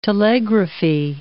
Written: telegraphy

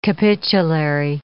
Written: capitulary

